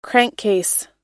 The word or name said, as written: crankcase